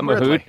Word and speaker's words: Mahut: Did I pronounce Mahut right? Is that how you say it? – I think you didn't pronounce Isner right. – Isner? – You pronounced Mahut right. – I got Mahut and blew Isner.